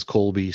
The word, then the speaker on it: Colby: Uh, my name is Colby, C O L B Y.